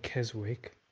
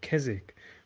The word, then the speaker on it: Keswick: so obviously the bob graham starts in keswick which is spelt with a w so you don't say keswick you say keswick but if you come from keswick you are a keswickian so that's just a little exception to it.